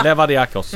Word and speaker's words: Levadiakos: Ni får uttala det. – Ja. – Ja, men vad heter de? – Leviadikos. – Ja. Leviadikos, precis. Och